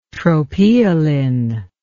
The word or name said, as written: tropaeolin